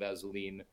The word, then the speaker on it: Assouline: Not everyone gets the pronunciation of Azzeline correct, but you did.